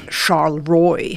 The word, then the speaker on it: Charleroi: Wie spricht man das aus? – Etwas anders. Charleroi heißt das da.